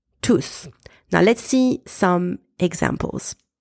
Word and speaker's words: tous: I am pronouncing T-O-U-S in that sentence tous because there is no noun after it.